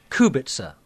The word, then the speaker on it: Kubica: Kubica has one of those little V symbols above the C, little accent that'll be, so it should sound like a TS, not a K. Kubica, then.